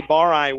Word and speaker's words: Bareis: Now top of the key, Barai with it. B-A-R-E-I-S. Different pronunciation for what the spelling would indicate.